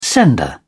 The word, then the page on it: sender: British English